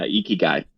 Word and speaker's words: Ikigai: So he's the CEO and founder of an asset management firm called Ikigai. Did I say that right? – That's right. Yeah, Ikigai.